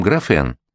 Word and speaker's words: графен: одномерной пленки под названием графен, не путать с графином, хотя по-английски звучит именно как графин, английское «е» читается как русское «и», не что иное, как один корж из того самого торта «Наполеон», один слой в графите.